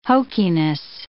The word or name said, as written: hokiness